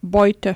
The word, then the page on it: booty: german